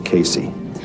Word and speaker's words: Casey: The name's Patrick Casey. – Could you spell that for me? – C-A-S-E-Y.